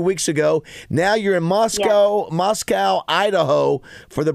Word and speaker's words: Moscow: Moscow. Locals. – That's how we pronounce. That's how we pronounce it here.